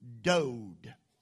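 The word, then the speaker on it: dode (דוד): I want to tell it to you, dode. It is spelled D-O-D, dode.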